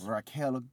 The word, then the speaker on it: Raquel: Raquel, you got to roll your R's. – Raquel Gonzalez.